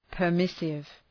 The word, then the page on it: permissive: pər’mısıv